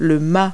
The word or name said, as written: mast